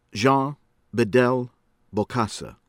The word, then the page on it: Jean Bedel Bokassa: American English